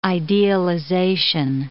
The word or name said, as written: idealization